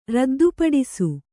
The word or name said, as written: ರದ್ದುಪಡಿಸು